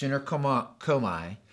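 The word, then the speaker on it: synergomai (συνέρχομαι): It's the Greek word that is pronounced synergomai, and it means this, to come together.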